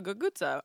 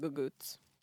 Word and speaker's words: cucuzza: It's called a guguza, or in my family we call it guguts. But it's spelled with the C, but it has the G sound.